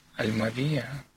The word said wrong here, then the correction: Альмавива: Альмавива. – Альмавива.